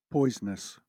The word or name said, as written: poisonous